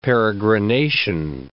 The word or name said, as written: peregrination